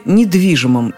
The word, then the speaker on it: недвижимый: Ударение всегда на первом «и», как и в слове «недвижимость».